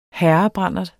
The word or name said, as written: herrebrandert